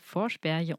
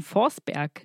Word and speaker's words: Forsberg: Das nächste Hörbuch, welches wir euch vorstellen möchten, ist Vorsperje und das verschwundene Mädchen. An dieser Stelle noch ein Hinweis. Man würde wahrscheinlich im Deutschen Forsberg lesen. Es äh, spricht sich allerdings wirklich schwedisch aus.